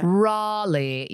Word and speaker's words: Raleigh: Well, I was in Raleigh it took me 80 years to remember how to say it. Raleigh. Yes.